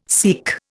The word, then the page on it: SEAQ: Indian